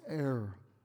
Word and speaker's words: heir: No, it's heir. Well, it's H. I say, well, the H is silent. Heir.